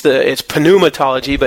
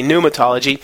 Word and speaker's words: pneumatology: You actually pronounce the P and the N together in Greek. So it's pneumatology, but in English we say pneumatology.